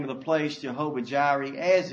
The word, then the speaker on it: Jehovah Jireh: And Abraham called the name of the place, do not miss this, Jehovah Jireh. That's how you say it. Jehovah Jireh.